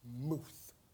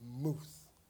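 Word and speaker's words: muth (מוּת): It's pronounced muth. M-U-W-T-H, muth. Sounds almost like mouth. Muth, that's death.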